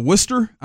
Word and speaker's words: Worcester: Marcelo Mayo was playing in Worcester. I don't know if is that how they say it. – Yeah.